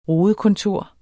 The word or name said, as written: rodekontor